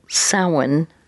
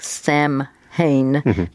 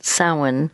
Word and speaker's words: Samhain: It originated, Halloween, with the ancient Celtic harvest festival, and that would be Irish, Scottish, Welsh, and it's called Samhain, spelled like it looks like Samhain, but it's called Samhain, which means festival of the dead.